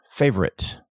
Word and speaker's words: favorite: Favorite. F-A-V-O-U-V. O-R-I-T-E. F-A-V-O-R-I-T-E. Or F-A-V-O-R-I-T-E. And of course, people want to add that O in the middle. There's the syncope. And it's pronounced without the O sound.